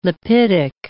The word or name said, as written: lipidic